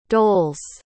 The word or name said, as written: dols